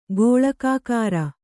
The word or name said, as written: ಗೋಳಕಾಕಾರ